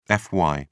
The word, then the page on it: fy: British English